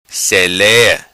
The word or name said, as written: Selea